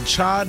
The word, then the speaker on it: chai: National Cha Day. – Okay. – Did I say that right? – Yes, like chai lattes.